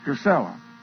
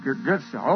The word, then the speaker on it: Gisela: I think, huh? Gisela? Is that correct? Gisela. Gisela, okay. Well,